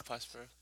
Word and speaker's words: Prospero: Pesero Burning, or Burning of Pesero. – Burning of Prospero. – Prospero. Yeah. I never said it right.